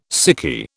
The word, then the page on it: sickie: British English